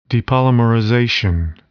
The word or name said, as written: depolymerization